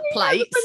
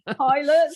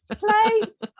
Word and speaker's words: Pilates: Plates. – Pilates. – Steve Wright used to say plates. You can do some plates. – Pilates. It's Pilates. Well, Pilates.